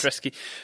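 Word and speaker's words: Hrádecký: And no thanks to Leverkusen's goalkeeper, Hadreski. Is that how I pronounce his name? – I believe so, yes. – Hadreski.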